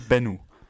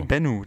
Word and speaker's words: Bennu: Der Benwe. ben, Benno. Nee, Bennu. – Das wird Bennu ausgesprochen.